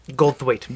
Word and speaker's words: Goldthwait: We're talking about Willow Creek from 2013, directed by Bobcat Goldthwait. – Written and directed. – Written and directed by Bobcat Goldthwait, who, if you don't... – Goldthwait.